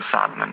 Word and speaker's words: psalmen: Ja, men till exempel så har vi ord som psalm till exempel, eller ljud, där vi då stavar LJ på ljud och psalmen med P, men vi uttalar inte P, det är liksom stumt.